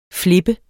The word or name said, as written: flippe